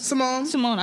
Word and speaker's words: Simone: Simon? – What are you talking about? – Biles. – Simone. – I said, what did I say, Simon? – I'm like Simone.